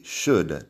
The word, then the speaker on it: should: Would, could y should también, de deberías. La L no suena.